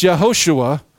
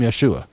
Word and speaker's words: Yeshua: And it may have been pronounced Yehoshua, like Joshua, before Babylon, but after Babylon, when Jesus walked the face of the earth, they called him Yeshua.